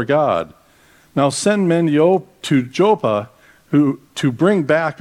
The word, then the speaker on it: Joppa: He told them everything that had happened and sent them off to, it's pronounced Joppa.